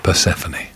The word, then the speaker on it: Persephone: tells me should be pronounced Persephone.